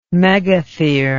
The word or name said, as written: megathere